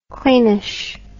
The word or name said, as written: cleanish